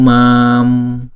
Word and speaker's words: mam: Abhishen chatu mam. Chữ mam là chữ A chúng ta đọc là hai nhịp ha.